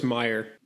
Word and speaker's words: Meyer: It's all laid out on paper of a perpetual motion machine created by American inventor Stanley Allen Mayer. – I think it's pronounced Meyer. – Meyer, thank you.